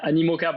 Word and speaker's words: Animoca: So Pixel Owl was acquired by, I don't know how to pronounce this, Amicoa? – Animoca Brands. – Okay, there you go.